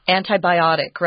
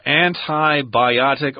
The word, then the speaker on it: antibiotic: Antibiotic or antibiotic. Both are correct. – Yeah. – Both are acceptable. But I tend to say antibiotic because biotic has the I sound there.